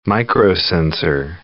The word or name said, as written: microsensor